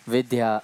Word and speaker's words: Vidya: She writes V-I-D-Y-A. – Ona to píše V-I-D-Y-A. – But we pronounce it V-I-D-H-Y-A. Ale my to, Vidya, pro ve skutečnosti vyslané s tím dh, dha, vidya.